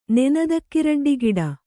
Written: ನೆನದಕ್ಕಿರಡ್ಡಿಗಿಡ

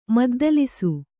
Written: ಮದ್ದಲಿಸು

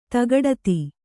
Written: ತಗಡತಿ

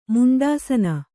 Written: ಮುಂಡಾಸನ

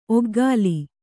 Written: ಒಗ್ಗಾಲಿ

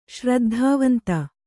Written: ಶ್ರದ್ಧಾವಂತ

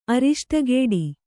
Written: ಅರಿಷ್ಟಗೇಡಿ

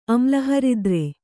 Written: ಅಮ್ಲಹರಿದ್ರೆ

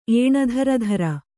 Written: ಏಣಧರಧರ

